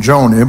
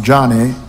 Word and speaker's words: Joni: Born the youngest of four daughters in 1949, Johnny Erickson Tada, I used to call her name Joni, but her name is pronounced Johnny after her father.